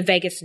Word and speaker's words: vagus: I want to jump really quick to the vagus nerve.